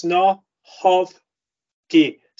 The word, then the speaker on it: Snochowski: The C is silent, so it's Snochowski.